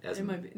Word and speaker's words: Asmodee: Is it not Asmodee? Is it Asmodee? – No, you're right with the pronunciation.